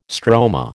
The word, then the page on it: stroma: American English